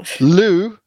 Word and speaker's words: Looe: Looe Looe is actually a place in Cornwall, folks. Don't laugh. It's spelled L O O E.